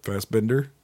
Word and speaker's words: Fassbender: Fassbender? – Yeah.